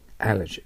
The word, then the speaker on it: allergy: アラジーがあると読まないといけません。アラジーです。アラジーというのは日本語の発音と全然違うので、アラジーという発音を練習していなければ、リスニングセクションでネイティブの人が発音したときにあなたは全く意味が取れないと思います。 Allergy です。